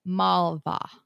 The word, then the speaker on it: Malva: Not saying she's the end be all end all, but she's the only one I've heard pronounce this other than people saying their own thing. So I am going with Malva.